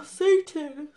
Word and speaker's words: Seyton: Okay, so he calls for Satan, which in the script, it's spelt S-E-Y-T-O-N, so it's not spelt like Satan, like S-A-T-A-N. So it sounds like he's calling the devil Satan.